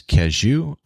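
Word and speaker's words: Cadieux: you go about as far west as Cajou, and that's not how it's spelled. It's French. So it's C-A-D-I-E-U-X. Cajou. They say Cajou here in Detroit.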